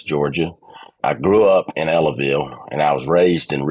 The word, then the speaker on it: Ellaville: You grew up in a town called Ellaville, Georgia. Am I saying that right? – Ellaville, yep. That's correct.